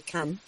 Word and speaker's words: Cannes: Doctor Fernando, pues hoy seguimos hablando del Festival de Cannes, el Festival de Cine, o de Cannes, como se debería pronunciar.